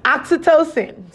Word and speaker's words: oxytocin: Did I say it right? Hold on. – Oxytocin.